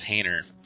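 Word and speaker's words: Hajner: It's H-A-J-N-E-R. Pronounced Hayner.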